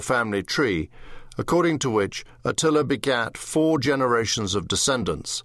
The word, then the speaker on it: Attila: Attila, the stress in Hungary is on the first syllable, which is rounded until it is almost an O, Attila, is a common boy's name.